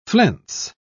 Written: flense

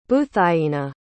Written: buthainah

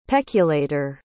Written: peculator

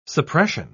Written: suppression

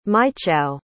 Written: mychau

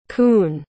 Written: Kuhn

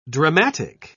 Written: dramatic

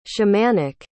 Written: shamanic

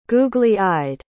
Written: googly-eyed

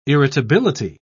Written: irritability

